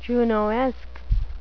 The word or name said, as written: junoesque